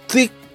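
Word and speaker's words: تكة: پرسیدم تلفظ درست این غذا چیه گفت تیک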